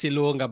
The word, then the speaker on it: Ilunga: Yes, it's Ilunga, but I don't mind.